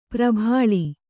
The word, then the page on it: ಪ್ರಭಾಳಿ: prabhāḷi